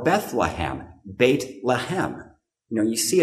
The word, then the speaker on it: Beth: It's called Beit, B-E-T-H. It looks like Beth, but it's actually pronounced Beit.